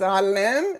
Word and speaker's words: Allam: My last name is Allam, and it has the Ayn, which is a letter in Arabic that doesn't exist in English.